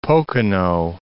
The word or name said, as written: Pocono Mountains